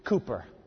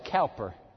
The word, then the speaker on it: Cowper: His name is William Cowper. It's spelled Cowper.